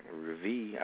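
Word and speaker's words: rêve: I was already dreamt, so uh, deja reve, reve. I don't know exactly. – I can't reve, reve. It's right. Reve. You said it right.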